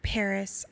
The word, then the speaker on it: PVRIS: Paris.